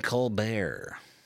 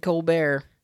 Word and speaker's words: Colbert: Is it the Stephen T is silent. – Colbert. In what? – In Colbert. – Oh, I said that. Colbert. – Yeah. – Oh, why'd you make it sound like I said it? – That's what it says at the thing, because it's spelled with a T at the end.